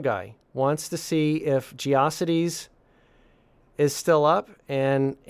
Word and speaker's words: GeoCities: I always pronounced it to rhyme with atrocities, so maybe we should say it's GeoCities.